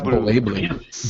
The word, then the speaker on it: BlazBlue: Like, because isn't, like, BlazBlue, which I was just reading its Wikipedia page earlier today, apparently the Z is silent in Japanese reading. It's BlazBlue. But it was always seen as a spiritual successor to Guilty Gear, and it's like, now there's just a new Guilty Gear.